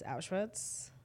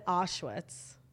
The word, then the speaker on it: Auschwitz: I know this is an important story, but like, how did you just pronounce Auschwitz? – Auschwitz? – You said Auschwitz. – Listen. – Just, not that it matters, but I feel like it's Auschwitz.